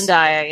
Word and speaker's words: Zendaya: is it Zendaya? – Yes. – Zendaya, yeah. – Oh, wow, I got it right.